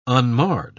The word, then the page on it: unmarred: British English